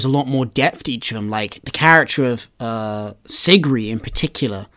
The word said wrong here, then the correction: Sigrid: I'll be honest, I pronounced it Sigrid. I was going to be like, it's such a... – No, it is Sigrid, you're right. I just forgot the last letter.